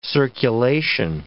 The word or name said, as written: circulation